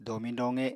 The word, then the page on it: domiñ-doqe: apatani